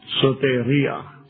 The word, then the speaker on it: soteria: This is how it's pronounced. It's important you understand this. – Soteria. – Soteria. – Soteria. – And that's how salvation is Soteria.